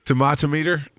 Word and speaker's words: tomatometer: The tetometer is tomatometer. There we go. I said it right.